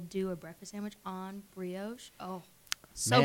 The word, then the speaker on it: brioche: It's brioche.